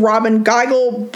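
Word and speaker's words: Robyn Gigl: And I literally looked that up so that I could make sure that I had the pronunciation of that right. It's attorney and LGBTQIA plus activist Robin Geigel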